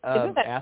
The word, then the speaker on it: athame: I say Athame because that's the Midwestern pronunciation. That's how I like to say it. Athame is, I think, how they say it here in California. And sometimes I will say it both ways without thinking about it. a Sammy is most likely, or some variation of a Sammy is most likely the original pronunciation of the word.